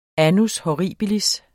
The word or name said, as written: annus horribilis